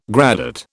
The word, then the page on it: gradate: British English